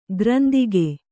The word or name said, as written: ಗ್ರಂದಿಗೆ